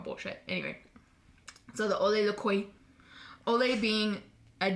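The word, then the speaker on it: Ole Lukøje: Like LaCroix? – I was close. – What is it? – Ole Lucroix. – Ole Lucroix. You did really good.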